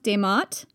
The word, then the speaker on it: D'mt: So one possible contender is, I think it's pronounced Demat. It's spelled D'MT.